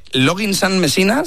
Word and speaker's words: Loggins and Messina: muy buen tema este, muy buen tema y quizá no demasiado, no demasiado conocido, de Loggins and Messina, se dice así.